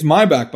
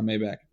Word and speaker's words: Maybach: It's Maybach, by the way. Rick Ross and Jay-Z were saying it wrong the whole time. It's not Maybach.